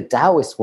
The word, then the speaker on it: Taoist: And the way the Taoist one's not useful, if my pronunciation shit, George, please tell me.